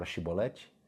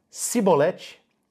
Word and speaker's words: Shibolete: Você sabe o que significa a palavra Shibolete? É uma espiga de grãos. É uma espiga de grãos. Por essa palavrinha, Shibolete. Se ele dissesse Sibolete, sem conseguir pronunciar corretamente a palavra, prendiam-no e matavam-no no lugar de passagem do Jordão.